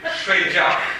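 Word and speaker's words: Shui jiao (睡觉): shu jiao. – Allora, questo è sbagliato. Shui jiao. Ricordate che era un i si pronuncia wei, quindi quel sh un i si pronuncia shui.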